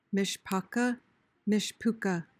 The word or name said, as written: mishpocha